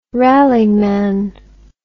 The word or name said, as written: rallyman